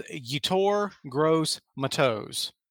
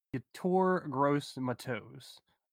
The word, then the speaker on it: Yetur Gross-Matos: I can actually pronounce it because I took the time to do the research and looked it up phonetically. It is Yetur Gross-Matos. – Yetur Gross-Matos.